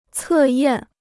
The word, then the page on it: 测验: cè yàn